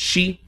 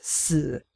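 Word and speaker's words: shi (四): And it's a lot easier to figure out why there's the fear of the number 4, because it's pronounced, in Japanese, it's pronounced shi, which is the same way that the word death is pronounced.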